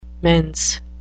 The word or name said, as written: mense